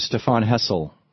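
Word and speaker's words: Stéphane Hessel: And we're talking with Stefan Hessel.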